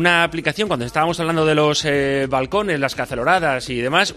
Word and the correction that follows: caceroladas: una aplicación para hacer caceloradas. ¿Cómo se dice? Caceroladas.